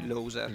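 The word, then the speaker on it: Low-Sir: Allora, adesso facciamo un pezzo che si intitola Loser, scritto però non all'inglese, scritto Low, L-O-W, trattino, Sir. Che si legge Loser.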